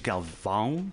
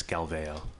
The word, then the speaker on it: Galvão: In Portuguese, Galvão, but in the bastardized English that I've transformed it to, it's Galveo.